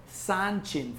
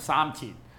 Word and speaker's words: Sanchin: And that's why in traditional Okinawan karate, you have the Sanchin form, which is just a Japanese pronunciation of Samchin, all right?